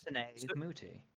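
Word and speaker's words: Netane Muti: Natane Muti? – Oh, yes. Yes, yes, yes. – Natane Muti.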